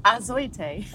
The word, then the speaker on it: Azoitei: Azoitei. – Azoitei. I know that. – Now spell it. – A Z O I. – Well done. – T E I. – You got it right.